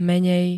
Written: menej

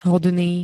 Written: hodný

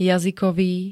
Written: jazykový

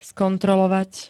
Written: skontrolovať

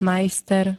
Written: majster

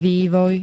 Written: vývoj

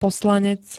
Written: poslanec

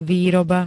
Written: výroba

